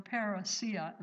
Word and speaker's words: parousia: And you will recognize this Greek word because I remember hearing this even from Brother Astle years ago, the parousia, or the parousia, it looks like it's better pronounced that way.